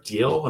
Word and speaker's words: Diehl: Deal, and Deal is spelled D-I-E-H-L, and it's called Resurrecting Nagasaki.